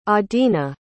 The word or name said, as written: adina